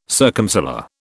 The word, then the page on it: circumsolar: British English